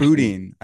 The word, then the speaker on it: boudin: What about boudin? – I recently found out about that. – So it's called boudin. It's spelled with an I, but it's actually like, it's like Lieutenant Dan. – Yeah. – Boudin.